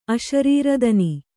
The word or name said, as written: ಅಶರೀರದನಿ